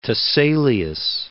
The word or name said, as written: Tiselius